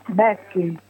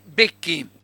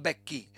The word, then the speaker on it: Becchi: Becchi. – Chi sei? – Silvana. – Silvana. Le becchi, non becchi, becchi, senza accento sulla E.